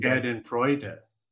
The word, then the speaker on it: Schadenfreude: That's called Schadenfreude. Am I pronouncing that word correctly? Schadenfreude.